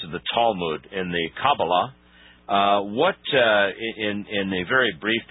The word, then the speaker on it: Kabbalah: I was never given any information about this whole business of the, do you pronounce it the Jewish Kabbalah or Kabbalah? – The correct pronunciation is the Kabbalah. – Kabbalah. – But there's a kind of an anglicized version, which is the Kabbalah.